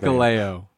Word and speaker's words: Hikuleo: Chris Bay and Hakuleo. Oh god, I can't pronounce it. Hikuleo. – Hikuleo.